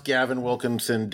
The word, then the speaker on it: Gavin Wilkinson: The house Gavin Wilkinson didn't build. Did I say his name wrong, too? – No, that was good.